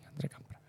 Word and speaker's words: André Campra: que se pronuncia así, André Campra.